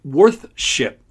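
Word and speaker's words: weorthscipe: It's W-E-O-R-T-H-S-C-I-P-E, pronounced worthship.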